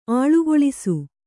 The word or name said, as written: ಆಳುಗೊಳಿಸು